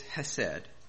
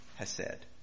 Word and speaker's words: chesed: That word is hesed, C-H-E-S-E-D, hesed. The C is silent. I don't know why.